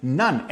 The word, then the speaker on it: nun: The psalmist precedes the verse in Psalms 119:105 with the Hebrew letter that could be pronounced as nun, N-U-N, which is interpreted as never-ending confidence in God's word.